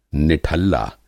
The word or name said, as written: निठल्ला